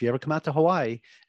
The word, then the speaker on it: Hawaii: Just turn the W into a V. – Hawaii. – Yep. That's how you would properly pronounce it.